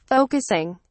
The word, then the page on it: focusing: British English